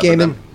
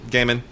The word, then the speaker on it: Gaiman: Well, speaking of Marvel, we also have Marvel Knights Eternals, written by Neil Gaiman, or Gaiman. I never know how you pronounce that. – Gaiman. – Gaiman.